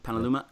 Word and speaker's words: Petaluma: First played August 1st, 1991 in Petaluma. Am I saying that right? California? – Mm-hmm. Petaluma.